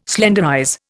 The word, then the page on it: slenderize: Indian